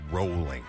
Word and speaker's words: Rowling: It rhymes with bowling, I've been told. And that is the proper way to say it. J.K. Rowling.